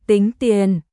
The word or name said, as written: Tính tiền